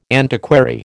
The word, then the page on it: antiquary: American English